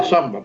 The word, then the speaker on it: zamba: Que ellos dicen la samba, pero que no es la samba brasileña, ¿eh? Que se escribe zamba con Z, zamba con Z, de zapato, ¿eh?